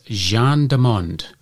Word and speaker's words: gens du monde: And this week's Word of the Week is Gendarmonde. And that is spelt G-E-N-S-D-U-M-O-N-D-E.